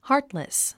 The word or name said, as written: heartless